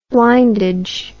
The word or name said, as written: blindage